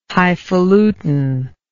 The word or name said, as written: hifalutin